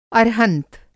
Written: अर्हंत

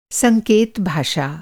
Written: संकेत भाषा

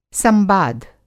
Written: संबाध

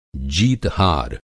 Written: जीत-हार

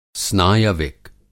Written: स्नायविक

